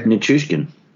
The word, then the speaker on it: Nichushkin: Nichushkin.